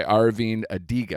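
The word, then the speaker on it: Aravind Adiga: So this was a New York Times bestseller by Arvind Adiga. I hope I'm saying that correctly. That you are.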